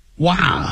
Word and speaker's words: wow: Jane 先说了 ,Wow, wow. 这个是 wow, 先念啊的音 ,wow, wow. 不要念成 wow, wow 的时候嘴巴没有全开，那是凹的音 ,wow.